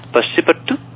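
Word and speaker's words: passe-partout: Esto no sé cómo se pronuncia. Passepartout. – Ah, sí, correcto.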